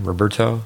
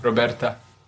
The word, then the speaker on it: Roberta: But I would say if Serena had, say her name again, Roberto? – Roberta.